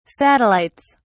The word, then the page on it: satellites: British English